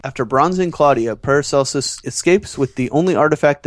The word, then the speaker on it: Paracelsus: This week's episode of Warehouse 13 allowed us to get to know Season 4's Big Bad, whose name we discovered is pronounced Paracelsus.